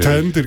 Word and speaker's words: Tender: Und zwar haben wir Fender Ender, oder wie du es genau aussprechen? – Tender Ender. – Tender, ganz normal. – Kommen nicht mit Fender, das ist ganz schlecht.